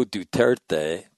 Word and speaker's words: Duterte: However, regional analysts warn not to assume that Philippine President Rodrigo Duterte, Grant taught me how to say his name, is signaling a desire to return to the way things were.